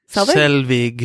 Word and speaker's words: Selvig: So at the beginning of the movie, we're introduced to Jane Foster and Eric Selvig. – Did I say that right this time? – I think so.